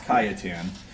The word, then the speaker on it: Cajetan: But up until that point, even Cardinal Cajetan, and I'll show you how you spell it because it's not spelled like you would imagine. Cardinal Cajetan, T-A-N, it's pronounced Cajetan.